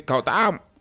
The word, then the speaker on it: Gautam: So, you have to say Gautam.